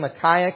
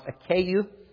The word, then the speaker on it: Achaia: Now, this name Achaia can either be pronounced Achaia or Achaia or Achaia, whichever you prefer.